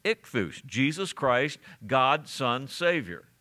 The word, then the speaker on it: Ichthus: Ichthus is the way you pronounce that.